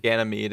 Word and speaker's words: Ganymede: I don't think it's a hard Y. Ganymede. – Ganymede is how I hear it pronounced. I actually thought it was Gana, like, spelled like that, but it's not.